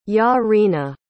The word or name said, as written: Yaryna